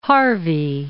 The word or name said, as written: Harvey